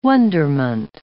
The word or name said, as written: wonderment